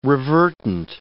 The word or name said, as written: revertant